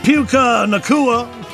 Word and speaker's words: Puka Nacua: Earlier, talking about uh, Puka Nacua. Yeah, yeah, take a look it up. Yeah, you do pronounce it Puka. So it is Puka Nacua and uh, he's like uh, a Samoan.